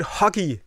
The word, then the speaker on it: hygge: And, I mean, you can pronounce it Hygge, you can pronounce it I've even heard huggy as a pronunciation.